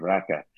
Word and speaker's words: raki: You know, there are very similar type drinks around the world, but they love their raka, and that's spelled R-A-K-I, but there's a kind of an A sound at the end of it, a short A sound, raka, and it was delicious.